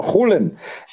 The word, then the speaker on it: Julen: It's, for those that study Spanish, you may be surprised because it's a J is Ju, no, Julen. But in he's obviously from the Basque country. And in Basque, Julen.